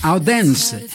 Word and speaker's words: Odense: Andersen, Odense.